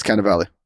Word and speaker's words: Cannavale: Did I say his name right? – I think it's Cannavale. Either way.